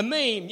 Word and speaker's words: meme: M-E-M-E. How do you pronounce it? Emmy, you know, what do you call it, these little, you know, not an emoji, but a picture that is like meme. A meme, yeah, a meme.